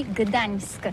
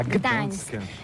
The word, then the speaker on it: Gdańsk: Alors d'abord, comment prononce-t-on bien? Ça s'écrit G-D-A-N-S-K. On dit Gdansk ou Gdansk, on prononce le G ou pas? – Ah oui, c'est pas très facile pour des étrangers, mais remarque bien que la langue polonaise, c'est la langue où on prononce chaque lettre qu'on écrit. C'est pourquoi on dit Gdańsk.